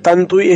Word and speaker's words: Tantui: Bueno, Tantui es Tantui. Sí, pues, es Tantui de 12 líneas, porque Tantui es un estilo. No, Tantui, en realidad es la forma de pronunciarlo.